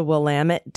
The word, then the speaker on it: Willamette: It's the Willamette Dammit River. – Willamette Dammit River. I love it. See, this is my first time, so I get to learn. Willamette Dammit.